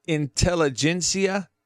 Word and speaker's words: intelligentsia: Intelligentsia. – Okay, am I saying it right? Intelligentsia? – Yeah. – That's right. – I don't know that word. I got to learn that word. Intelligentsia.